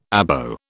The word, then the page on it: Abo: British English